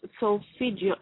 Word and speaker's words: solfeggio: It's solfeggio, S-O-L-F-E-G-G-I-O.